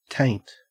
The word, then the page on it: taint: Australian English